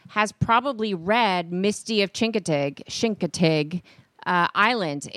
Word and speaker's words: Chincoteague: If you're a local. – Yes. – It's not Chincoteague. – No. We were saying it wrong all these years, Tony Bruno. – But a guy finally had a t-shirt on that said S-H-I-N-K, Chink-O-Tig, T-I-G. – Right. – So that people pronounce it properly.